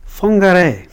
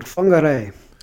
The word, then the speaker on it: Whangarei: Whangarei.